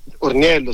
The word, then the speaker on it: Orniello: Allora, oggi abbiamo il frassino Orniello, è la pianta della settimana. Ho detto bene? – Esatto, Orniello, sì sì, frassino Orniello, una pianta bellissima.